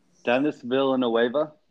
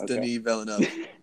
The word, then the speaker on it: Denis Villeneuve: It's spelled Dennis. – Dennis Villeneuve. – Yes. Yes. – But it's Denis Villeneuve.